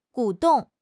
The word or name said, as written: 鼓动